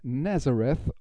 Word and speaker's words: Nazareth: Die Band heißt Nazareth oder korrekterweise auf Englisch ausgesprochen Nazareth und der Song, den wir uns anhören, heißt Expect No Mercy.